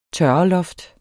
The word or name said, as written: tørreloft